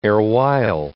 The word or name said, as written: erewhile